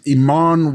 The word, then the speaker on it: Eamonn: Did I say it right? I don't know if I said it right. – Spell it. – E-A-M-O-N-N. – Yes. – I did? – Yeah, sure.